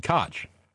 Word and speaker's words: Koch: It ended its time because of a Democrat, Ed Koch. Not pronounced with a long O like Coke, I know. Like crotch without the R.